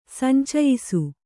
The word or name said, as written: ಸಂಚಯ್ಸು